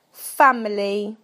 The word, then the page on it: family: British English